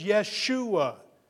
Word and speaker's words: Yeshua: To pronounce it as it is written is Yeshua.